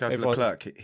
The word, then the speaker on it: Leclerc: I've noticed that he pronounces his name different to how everyone pronounces his name. It's like Leclerc. He says Leclerc. – He says Leclerc. And nobody else says that.